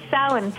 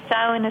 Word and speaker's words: Samhain: And it's Samhain, is what you call it. Ihahauna is Halloween. Samhain is how you pronounce it.